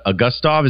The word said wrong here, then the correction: Augustave: I think it's Augustave. – Augustave, okay, because I've heard it about three different ways.